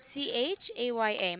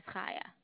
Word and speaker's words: Chaya: Chaya. My name is Chaya. – Chaya. Chaya. Oh, so Chaya with a C, like Hanukkah, mm-hmm.